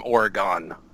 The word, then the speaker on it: Oregon: You can say I'm from Oregon. That's how most people pronounce it. Not from Oregon. – Yeah, Oregon. Yeah, I got that one. – Say, all right. – Yeah, we don't pronounce it that way here. – Yeah. Well, that's good. That's good to know. – I never heard anybody pronounce it that way, as a matter of fact. Yes, Oregon, because they see the O-N. It's quite common.